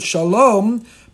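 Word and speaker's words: Shalom (שלום): Shin Lamed Mem, we pronounce it Shalom, but it's missing the Vav.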